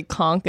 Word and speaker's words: conch: We called each other Conch, which is actually conch is how you say it, conch shell.